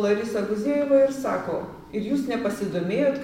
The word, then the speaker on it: Guzėjeva: Bet mane, paskutinį metu žiūrint, ta laidos vedėja Guzėjeva, ar taip pavardė? – Guzėjeva Larisa. – Guzėjeva Larisa Guzėjeva ištarė tokią frazę.